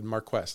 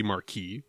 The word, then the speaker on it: Marquess: I said, how do you pronounce this? And Google said Marquess. And I said, I don't think so. – Are you sure? It sounds like it'd be Marquis, right? – It does, but it's not.